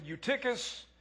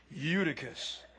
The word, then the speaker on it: Eutychus: Took me a long time to get that name right. Some pronounce it Eutychus. I am from Scottsburg, Virginia, and we say Eutychus, who was sinking into a deep sleep as Paul talked on and on.